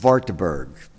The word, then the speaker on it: Wartburg: They took him away to a castle in Wartburg. All these are W's are pronounced with V, depending on how your German pronunciation is, to a castle.